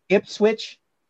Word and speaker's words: Ipswich: Just Ipswich. Did I say that correctly? – Yeah. Ipswich.